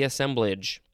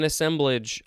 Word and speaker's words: assemblage: And it looks like the pronunciation guide has two ways. Let's see, assemblage or assemblage. Ooh, that's a fancy way to say it.